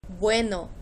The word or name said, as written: bueno